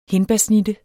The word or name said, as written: hindbærsnitte